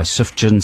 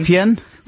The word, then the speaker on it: Sufjan: I can't even be bad properly, because the next record's by Sufjan Stevens. Is that how you say his name? – Sufjan? – Sufjan.